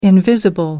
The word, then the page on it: invisible: American English